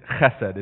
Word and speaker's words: chesed: The word is chesed. It's spelled C-phlegm-E-S-E-D. And the Bible talks about chesed love. It's a Hebrew word.